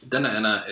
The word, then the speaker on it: Dena'ina: Yeah, so the Dena'ina is the inland dialect pronunciation. And you'll hear Dena'ina, especially from older recordings of people like Shem Pete or Fedosia Sakaloff, who speak upper inlet and outer inlet, respectively.